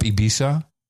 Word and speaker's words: Ibiza: I don't know why they say Ibiza because it's spelled I-B-I-Z-A. Where does the tha come in? It should be za. Ibiza. – Well, I think the Spaniards say Ibiza.